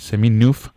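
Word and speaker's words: Chemin Neuf: Y lo primero de todo es preguntarte por la comunidad a la que perteneces, esta comunidad que no sé si lo pronuncio bien, Seminuf, eh, que es una comunidad francesa. bien.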